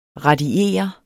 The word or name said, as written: radiere